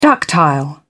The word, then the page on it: ductile: British English